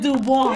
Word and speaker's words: Du Bois: So you pronounced it Dubois. – Yeah. Dubois. – What is D-U-B-O-I-S? – Oh, that's the same way you spell W-E-B. – Yeah, Du Bois. – Du Bois.